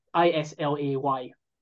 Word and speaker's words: Islay: I think this is Isla, or it's spelled Isla. It's Islay.